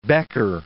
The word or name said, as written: Becker